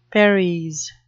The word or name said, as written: paries